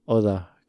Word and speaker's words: Oda: See, it's not Oda, because Oda, because you pronounce D-A kind of like in Spanish.